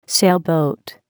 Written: sailboat

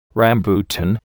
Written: rambutan

